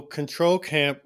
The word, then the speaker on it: CTRL Camp: So Control Camp, which is spelled CTRL Camp, like Control Alt Delete.